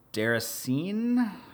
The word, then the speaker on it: Déraciné: Um, second is, I don't even know how to pronounce this, Déraciné, which is the VR Soul game from the Souls developers. Is that correct, right? Correct, um, correct.